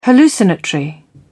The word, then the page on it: hallucinatory: British English